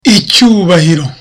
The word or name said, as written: icyubahiro